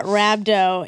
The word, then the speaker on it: rhabdo: Yeah, rhabdo.